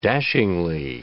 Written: dashingly

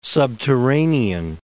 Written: subterranean

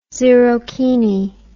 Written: zerokini

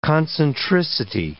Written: concentricity

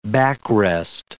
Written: backrest

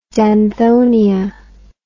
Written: danthonia

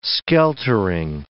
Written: skeltering

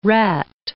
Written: rat

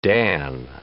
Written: dan

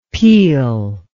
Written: Pele

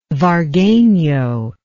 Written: vargueno

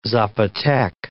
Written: Zapotec

